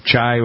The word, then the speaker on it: chai: How many people have you had anything made out of this chi, chai, whatever? What did you say to pronounce it? Chi? – Chai. – Chai. – C-H-A-I.